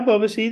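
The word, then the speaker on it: Ebobisse: Yeah, Boba C. That's how you pronounce his name.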